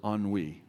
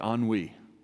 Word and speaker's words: ennui: In Latin, this word is ennui. Everybody say ennui. Anybody want to try to spell it? E-N-N-U-I. Ennui. It's a feeling of weariness and dissatisfaction.